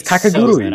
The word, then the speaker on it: Kakegurui: It's Kakegurui.